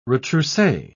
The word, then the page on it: retrousse: British English